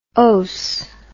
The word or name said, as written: ose